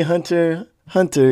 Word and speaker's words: Hunter x Hunter: because in Japan, technically the X is silent. So if you see Hunter, Hunter is not Hunter X Hunter, it's just Hunter Hunter.